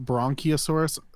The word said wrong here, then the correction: brachiosaurus: That's, I said it wrong. It is brachiosaurus. There's no N. So, close.